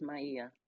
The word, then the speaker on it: Maia: M-A-I-A. Okay. – Yes. Yes, Maya.